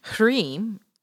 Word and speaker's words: Hrim: Hrim, pronounced H-R-E-E-M, is the seed sound for the illusion of this reality.